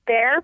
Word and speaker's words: Baehr: Catherine, can I get your last name so the tickets are at Will Bear. – Call? Spell it. – Bear, B-A-E-H-R.